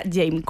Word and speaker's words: Jaime: que en realidad el nombre artístico se pronuncia James, como yo amo en francés, creo, aunque eso Alicia es.